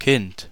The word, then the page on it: Kind: /kɪnt/